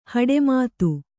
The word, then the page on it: ಹಡೆಮಾತು: haḍe mātu